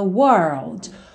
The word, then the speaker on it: world: World, que vai ter aí uma pronúncia ou uma dica de pronúncia, um truque de pronúncia muito parecido com girl, certo? Girl, lembra que a gente faz primeiro até o R e depois põe o L? World também, tá?